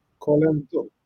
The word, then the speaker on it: Collenottolo: So, Colen. No, I lost. Collenottolo. Collenottolo. Collenottolo. Thank you. Collenottolo. – What does it mean, by the way? Uh, Collenottolo is the name of the place where is uh, located the winery.